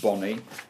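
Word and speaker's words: Bonne: I'm sure he's pronounced Bonnie, who they signed from Colchester in the summer.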